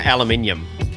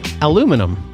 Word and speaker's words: aluminium: See, I say aluminium. – Aluminum. – Aluminum. You're a moron. Aluminum. Oh, I'm so proper. Aluminum pole. It's aluminium. – That's how the English say it, too, though. Aluminium. – Aluminium. – It's aluminum. – No, it's aluminium. Like, you look at the way it's spelt, it's aluminium. – Aluminum.